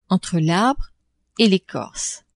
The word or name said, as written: entre l'arbre et l'ecorce